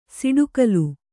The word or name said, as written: ಸಿಡುಕಲು